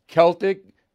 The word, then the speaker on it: Celtic: Celtic like Boston Celtics, but the correct pronunciation is Celtic, C E L T I C, Capri, C A P R I.